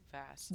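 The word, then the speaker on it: Vassa: Vasa, how do we say this? – I say Vasa. – Vasa, yeah. Vasa? Yeah, it's Vasa.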